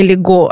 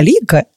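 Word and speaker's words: Līgo: Я тебе немножко поправлю, что не Лего, а Лига. – Э, лига, да, я все еще учу ударение, да. – В большинстве латышских слов все-таки ударение на первый слог падает, и мало того, лига, в основном латыши как раз этот праздник не называют.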